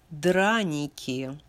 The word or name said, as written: Draniki